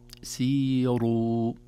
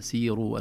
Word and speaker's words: سيروا: آخر تنبيه، قل سيروا، سيروا، أيضا الراء المضمومة إذا جاء قبلها كسرة أو ياء مادية مثل سيروا، الخاسرون، الكافرون، تميل إلى الترقيق،